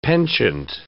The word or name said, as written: penchant